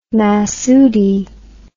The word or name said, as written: Masudi